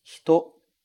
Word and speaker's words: hito: Veja que eu não falo hito, hito. Esse I quase some. A gente fala hito, hito, hito. É assim que o japonês realmente pronuncia essa palavra. Esse i quase desaparece.